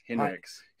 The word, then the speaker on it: Hinrichs: Hinrichs. – Hinrich, yeah, whatever. It rhymes with Ben Bricks.